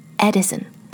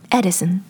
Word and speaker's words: Edison: 讲一讲男生的名字 Edison, 爱迪生。 So, 因为中文译过来是爱迪生，所以很多人都会很自然地把这个名字说成 Edison 这个名字其实在美国也是挺有名的，有很多人每个月的水电费都是给这家公司交。 So Edison 正确标准的发音是 Edison E-D-I-S-O-N Edison 而不是 Edison another similar name Eddie E-D-D-Y Eddie 不是 Eddie.